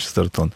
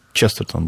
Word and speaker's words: Честертон: Уважаемые радиослушатели, я хочу покаяться, что я говорил «Честертон». А по-английски «Честертон» будет, да? Ну, тогда, в принципе, по идее, слово должно сохранять ударение оригинала, да.